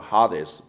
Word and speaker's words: Hades: Here, the heart of the earth in Hades, the Greek word Hades, but the English pronounced Hades, and Sheol, the Hebrew, translated in English, hell, H-E-L-L, or grave, before Jesus' resurrection.